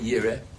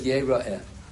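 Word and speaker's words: Yeroi (יראה): So the word is spelled Yud Reish Aleph Hei Yire, but we pronounce it Yeroi.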